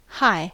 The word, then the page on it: hi: American English